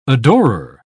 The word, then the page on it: adorer: British English